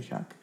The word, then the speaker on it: Shack: De Shack, ¿no? ¿Sí lo pronuncié bien? De Shack. Sí.